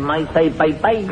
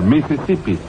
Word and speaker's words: Mississippi: La I no se pronuncia ahí. – Sí. – Mira, a ver, ahí lo dice, que hay un río que se llama igual, Maasai Paipai. – No, señor, Mississippi.